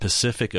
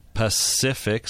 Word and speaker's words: Pacific: Do you know that Pacific Ocean, every sea in that is pronounced differently? Pacific, so that's a soft C. – Oh. – Pacific, that's the hard C.